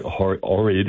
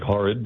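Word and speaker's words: Ohrid: And that Lake Orid, not pronounced probably as how beautiful as it is, Lake Horrid, but it looks absolutely... – The H is silent almost. It's awkward. – Yes, definitely keep the H silent.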